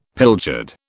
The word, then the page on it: pilchard: British English